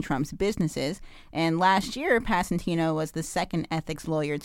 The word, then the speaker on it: Passantino: How's it spelled? – P-A-S-S-A-N-T-I-N-O. Passantino. – Passantino. – Okay, great. – You got it.